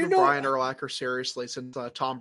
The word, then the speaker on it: Urlacher: It's Urlacher.